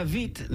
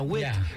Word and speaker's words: Witt: Well, it was Katarina Witt, not Katarina Witt, because the W is pronounced like a V.